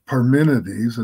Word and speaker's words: Parmenides: Parmenides, and that's the correct way to say it, the way you said it last night.